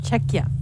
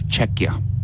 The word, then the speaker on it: Czechia: Czechia. – Czechia.